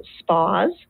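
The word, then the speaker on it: S'PAWS: So we pronounce it SPAS.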